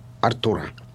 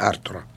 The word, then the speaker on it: Артур: До 7 лет Мерлин воспитывал Артура. Вообще, точнее, Артура. Ну, не является ошибкой, это русское ударение, а это принятое в Англии.